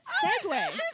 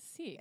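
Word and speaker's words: segue: clicked and i was like holy shit that's pronounced that's segway my entire life. – I must have never read that word. Yeah. Oh, or if I did, funny. I read it as seek.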